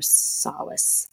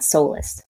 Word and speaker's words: solace: I would say solace. Word. Not soulless.